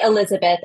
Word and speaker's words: Elisabeth: Yeah, I would say Elizabeth. I mean, in French, it would be a hard zz, but it's in French, they would spell it with an S, but it would still be pronounced the z instead of a soft S. So Elizabeth, yeah.